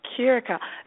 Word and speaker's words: Curaçao: Curaçao.